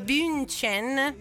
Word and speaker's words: Bündchen: La nuova casa di Gisele Bündchen, come cavolo si pronuncia? Bündchen, ma di fronte a quella di Tom Brady.